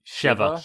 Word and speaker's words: Sheva: Sheva. – Shiva? – I think they pronounce it Shiva. – It was Sheva with an E.